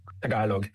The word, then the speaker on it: Tagalog: How do you say it again? Tagalong? – Tagalog, yes.